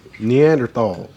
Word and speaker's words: Neanderthals: Alright, so you know there the you Nathaniels, humans, what we call Neanderthals. – In what? I said it wrong. – You said Neanderthals. – What did I say? – Nathaniels. That's a name.